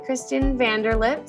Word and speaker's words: Kristin Vanderlip: I'm here with Kristin Vanderlip.